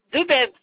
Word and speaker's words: Debeve: Debeuve. – Comment ça s'écrit, s'il vous plaît? – D-E-B-E-V-E. – D-E. D-E-V comme Valérie? – D-E-B-E-V-E.